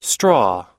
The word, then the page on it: straw: American English